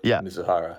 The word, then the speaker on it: Mizuhara: And then it was Mizuhara, is how you say his name? – Ipe Mizuhara. – Mizuhara, okay.